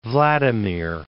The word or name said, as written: Vladimir